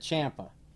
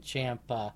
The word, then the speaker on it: Ciampa: And notice I said Ciampa. – Yep, because guess what? We found out the correct way to say it because Ciampa, apparently, he tweeted about the pronunciation. Very strange timing. 8 hours after our podcast dropped, approximately, give or take, he tweeted out, for all you ignorant podcasters out there and journalists, it's pronounced Ciampa.